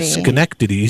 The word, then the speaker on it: Schenectady: Schenectady is even better.